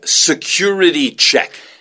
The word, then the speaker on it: security: 그래서 security 발음이요. security 이렇게 안, security 이렇게 안 들려요. security 이렇게 들리죠. – Yeah, security check. – 맞습니다. security. security처럼 들려요.